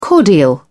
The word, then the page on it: cordial: British English